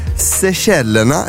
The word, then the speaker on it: Seychellerna: Om jag säger så här, Seychellerna, är det rätt uttal? – Mm. Ja, jättebra.